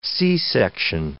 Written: C-section

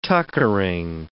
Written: tuckering

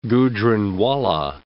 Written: Gujranwala

